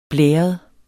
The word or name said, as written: blæret